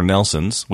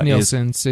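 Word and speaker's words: Nielsen: See, the Nielsens, or Nelsons, we've never figured out how to say that. – It's Nielsens. It's an I.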